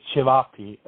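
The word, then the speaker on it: ćevapi: I believe it's pronounced Sepapi. It starts with the C. If so, can you explain to the listeners the dish is all about? – It's Cevapi. So it's like, yeah, so it's Cevapi, I think.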